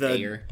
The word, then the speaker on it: Ayer: But I did just read that David, how do you pronounce his last name? I'm horrible with pronouncing. It's Ayer. – Yeah, the director? – Yeah. It's just Ayer. – I think it's Ayer.